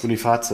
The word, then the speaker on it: Boniface: Und die, wie heißt der, äh, Boniface oder Boniface oder wie, wie spricht man das aus? Boniface, ja, klar.